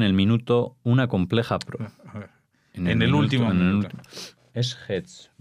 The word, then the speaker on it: Hetz: No te esfuerces en pronunciarlo como Z, sino es el sonido tz, Hetz. No sé, o sea, como no hay forma de hacerlo, por eso se escribe así.